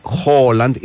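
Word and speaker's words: Haaland: Αλλά τόσο, τα παραδείγματα είναι ότι το Χάλαντ προφέρεται Χόλαντ. Είναι δύο, δεν είναι ένα.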